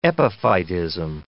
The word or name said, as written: epiphytism